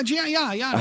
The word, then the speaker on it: Giannis: Oh, yeah, Giannis.